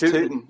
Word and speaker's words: Tootin: It's Tootin', like rootin' tootin'.